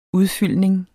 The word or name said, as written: udfyldning